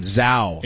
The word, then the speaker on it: Zhao: Yeah, apparently I was mispronouncing his name. Zhao.